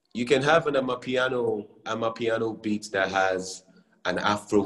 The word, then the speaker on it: Amapiano: It's called Amapiano, right? Like the South Yeah, Amapiano, South yes.